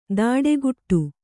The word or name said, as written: ದಾಡೆಗುಟ್ಟು